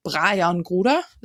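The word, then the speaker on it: Brajan Gruda: Und deswegen Brian Gruder ist richtig, ne? – Ja.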